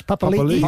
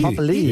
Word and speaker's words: Papali'i: and Josh Papali'i. Papali'i, yes.